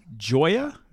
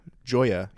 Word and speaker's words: Gioia: How would you pronounce G-I-O-I-A? – Joya? – Joya.